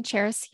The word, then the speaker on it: Charis: So you mentioned my podcast at the beginning, Charis Your Life, and my name is kind of tricky, but it's pronounced and spelled as if it's like the city Paris, but with a CH.